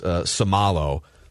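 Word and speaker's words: Isaac Seumalo: And then Isaac is pronounced Somalo.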